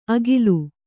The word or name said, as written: ಅಗಿಲು